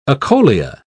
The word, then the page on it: acholia: British English